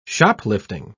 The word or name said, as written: shoplifting